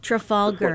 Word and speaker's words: Trafalgar: Trafalgar. Like the big square in the center of London.